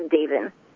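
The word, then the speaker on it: Deven: It's D-E-V-E-N. It looks like Devin, but it's pronounced Davin.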